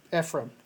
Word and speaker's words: Ephraim: Basically, these two guys, David Packhouse and Ephraim, did I say it right? – Ephraim, yes.